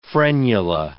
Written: frenula

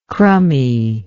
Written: crummy

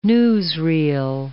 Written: newsreel

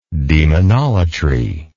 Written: demonolatrously

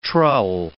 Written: trull

